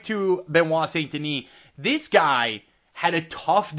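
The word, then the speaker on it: Benoît Saint-Denis: It's Benoit Saint-Denis, a minus 225. Actually, I assume that's actually Benoit Saint-Denis, if we're going with the French pronunciation.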